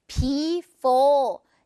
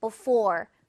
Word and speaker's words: before: 여러분, 이거 발음하실 때 before 이렇게 하지 마시고요. 강세가 for 이 부분에 있기 때문에 앞에 있는 be는 상대적으로 약하게 소리가 나서 before 아니고 before, before 이런 식으로 자주 발음되니까 그렇게 연습 많이 해보세요.